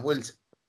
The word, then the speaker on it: Wheels: Will. Sí, lo dije bien, Wheels.